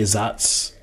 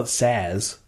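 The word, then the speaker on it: Saaz: We say Zatz because that's normally the way it's pronounced. It's spelled S-A-A-Z. I think we pronounce, I think the way we learn to pronounce it is Zatz, but some people do call it Saz.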